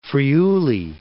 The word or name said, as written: Friuli